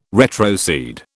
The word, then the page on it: retrocede: British English